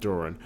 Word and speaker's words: Doran: Christine Love, Tom, um, John Duran, Doran. well, he's Canadian. I'm sure it's pronounced Doran.